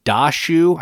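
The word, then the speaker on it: Daxu: Yeah, and the game is called Dashu. I think that's how you say it.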